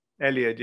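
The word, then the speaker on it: Eliud: Elliot, yeah, Kipchoge.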